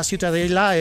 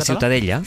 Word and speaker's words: Ciutadella: Para mí la Ciutadella es... ¿Cómo se pronuncia? – La Ciutadella.